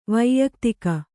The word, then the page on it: ವೈಯಕ್ತಿಕ: vaiyuktika